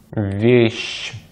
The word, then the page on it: вещь: Russian